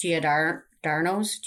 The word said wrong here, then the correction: Giordano's: Giordano's. Oh from, yeah, Giordano's.